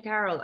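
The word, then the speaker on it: Carole: There's an E at the end of Carol.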